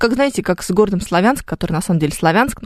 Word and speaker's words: Славянск: Да как угодно они там могут говорить, но он же Славянск. Ну, если мы откроем словарь, мы обнаружим там этот населенный пункт, который с ударением на букву «Я».